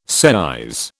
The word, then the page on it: seise: British English